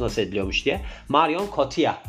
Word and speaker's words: Marion Cotillard: Yani İngilizcesine bakarsak Marion Cotilla diye de okunuyor. Ama biz Fransız olduğu için aktris tabii ki Fransızca telaffuzundan sorumluyuz.